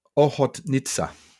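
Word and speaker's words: Ochotnica: Oh, I've got it written down phonetically. Ohotnitsa. Can you write that down? So basically, OHOT NITCA.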